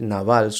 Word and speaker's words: Nabal: El, nombre, el nombre de, de Nabal, y varias ocasiones lo, he pronunciado incorrectamente, es Naval, solamente con una A.